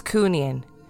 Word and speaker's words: Cooneen: My wife is from County Fermanagh, and I promised that I would let you know the pronunciation is Cooneen.